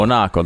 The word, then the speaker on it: Monaco: Monaco. This is how they say it. Monaco.